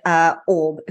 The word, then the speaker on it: Aub: So as I said, we are welcomed to the show with Aub, who has just told me to pronounce her name like Bob without the B, which is now going to stick in my head for the rest of the day.